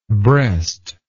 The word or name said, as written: breastless